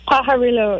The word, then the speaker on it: Pajarillo: And how do you pronounce it? It's a Pajarillo Verde? – Pajarillo, yeah.